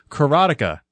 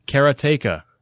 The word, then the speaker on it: Karateka: Karateka. No, I'm mispronouncing that. Karateka.